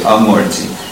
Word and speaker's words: amurti: But mantra murtim amurtikam, when you put the prefix a before a Sanskrit word, typically it negates, and which is different from long a, but the short a, amurti.